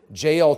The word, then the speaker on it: Jael: And the Bible says while he's asleep that Jael took a nail. It rhymes. Jael took a nail, one of those big tent pegs, and she went softly over to him.